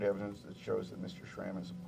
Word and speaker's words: SRAM: On page 45 of the blue brief, you say that the SRAM declaration, am I saying that right, SRAM? – Yes, sir.